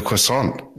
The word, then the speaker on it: croissant: of a croissant. Kate Reid is the founder and co-owner of Loon in Melbourne. Kate, how are you? – Very well. How are you? – Good. The croissant.